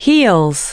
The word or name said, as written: heels